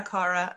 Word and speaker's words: Cara: Like he's like, I don't like, he was like when that Alessia Caro song, is that how you say her name? Alessia Cara.